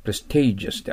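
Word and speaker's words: prestigious: その次は、派生語は、プレステージュスってアクセント後ろにあります。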